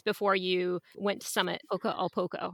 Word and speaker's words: poco a poco: So I watched your video of you and Will, sorry, your honeymoon climb to, is it Poca a Poca? Am I saying that right? – Poco a poco, little by little.